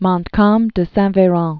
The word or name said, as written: Louis Joseph de Montcalm de Saint-Veran